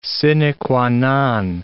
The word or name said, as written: sine qua non